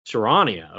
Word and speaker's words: Charania: Charania, okay.